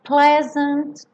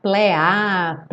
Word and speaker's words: pleasant: Be grateful for a pleasant trip. Cuidado com esse pleasant, para não falar plea, ple, como se fosse um E com acento agudo.